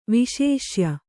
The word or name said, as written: ವಿಶೇಷ್ಯ